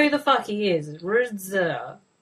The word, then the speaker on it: RZA: MC Gainey, Don Johnson, Joseph Gordon-Levitt, Kerry Washington, Anthony LaPaglia, Raza, Tom Wopet. – RZA, right? Pronounce things differently.